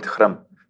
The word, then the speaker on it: Hrm: We call it Hrm. – How do you spell that? – Hrm. – Hrm. – H-R-M. – H-R-M.